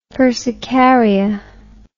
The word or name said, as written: persicaria